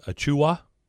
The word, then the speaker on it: Achiuwa: Anfernee Penny Hardaway has struck again, struck gold, a five-star recruit, Precious Achiuwa. I believe that's how you say it, Achiuwa.